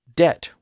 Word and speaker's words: debt: And he says that he abhors, quote, "such rackers of orthography as to speak doubt fine when he should say dabut, debt when he should pronounce debit, D-E-B-T, not D-E-T," end quote.